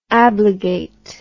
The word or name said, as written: ablegate